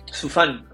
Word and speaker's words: Sufan: Sufan.